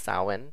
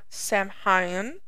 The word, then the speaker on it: Samhain: It's spelled Samhain. None of that is how you pronounce it, as you would read it. It is pronounced Samhain, Samhain, Not Samhain. – or Samhain, or Halloween. It is Samhain.